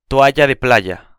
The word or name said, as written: toalla de playa